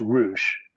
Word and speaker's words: Ruch: It's pronounced Roosh, but as you can probably imagine with a name like that, you get more pronunciation. That's what I said, wasn't it? Yeah. – It's definitely not how it was originally pronounced back in the day in Poland or wherever.